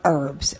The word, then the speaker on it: herbs: He's just talked about the place in our world over the years of herbs, and they've always been important. – And I think the only question we never asked him is why Anne pronounces it without an H on the beginning, and I pronounce it with an H on the beginning.